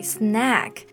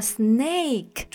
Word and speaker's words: snack: Exactly, snag. 那中间的这个发音其实是梅花音 a snag 就是我们的零食。如果你发成了 snake，中间用的这个 a 的音呢，它就会变成蛇的意思。